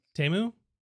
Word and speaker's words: Temu: Temu? – Temu? – I did it. – Temu. We said Temu all the time. – All the time. Every time. I always say, like, don't order off Temu.